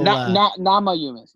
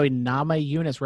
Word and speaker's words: Namajunas: How do you say Rose's last name? – Mamma Jamma. No, I'm joking. Yamanunis? – You added a little... – Namajunas. – There you go. Namajunas.